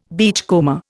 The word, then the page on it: beachcomber: Indian